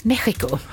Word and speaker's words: Mexiko: Mexiko. Bra uttal där på det.